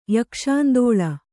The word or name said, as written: ಯಕ್ಷಾಂದೋಳ